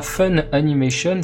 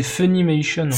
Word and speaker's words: Funimation: Eh, je vais être chiant, mais tu te trompes souvent, c'est pas Fun Animation, c'est Funimation.